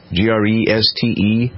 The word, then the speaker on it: Greste: I think his name is Peter Grest. Is that how you pronounce it? Greste.